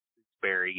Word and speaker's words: Stooksbury: You know, it's a, both a blessing and a curse to have the last name of Stooksbury.